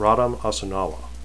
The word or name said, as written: rhadam